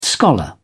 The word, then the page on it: scholar: British English